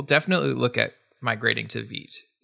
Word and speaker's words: Vite: And yes, Vite, pronounced Vite, it's from French, I believe, means fast. And it is. – Yes, Vite rhymes with sweet is my way of remembering it. Nick Nisi says Vite rhymes with feet.